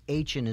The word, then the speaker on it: Aachen: So the first lawyer ad in any phone book would be A-H-N lawyer, because A-H-N is a town in Germany. – Oh, really? – And so it's spelled A-A-C-H-E-N.